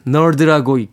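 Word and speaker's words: N.E.R.D: E NERD, 널드라고 읽죠? 널드의 Sooner or Later 듣습니다.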